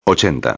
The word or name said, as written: 80